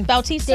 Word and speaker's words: Bautista: Dave Bautista? – Did I say it wrong? – Bautista. – David Bautista. – Oh, Bautista.